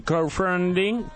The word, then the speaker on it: crowdfunding: ¿Cómo se dice? – Crowdfunding. – Hoy empecé a estudiar inglés, así que si me equivoco en la pronunciación, quiero que me corrijas, así cuando vaya a mi profesora, ella se sorprende. – Es crowdfunding. – Crowdfunding. ¿Bien ahí? – Un poquito mejor, sí, muy bien.